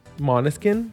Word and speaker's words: Måneskin: Måneskin? – Yes.